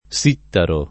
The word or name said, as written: Sittaro